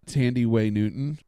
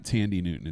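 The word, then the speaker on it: Thandiwe Newton: Tandy Way Newton. It's not Tandy Newton.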